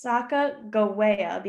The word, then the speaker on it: Sacagawea: And it's Sacagawea because in my research, there's not a soft G in the Hidatsa language, which translates to bird woman. So side note, there are a bunch of different spellings, but if we're going based on the true like translation, Sacaga is bird and it's spelled with a G.